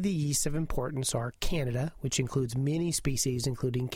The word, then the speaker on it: Candida: It's Candida, by the way, not Candida, which is sort of a George Bernard Shaw play, nor is it pronounced Canada, which is the country up north.